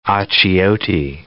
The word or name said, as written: achiote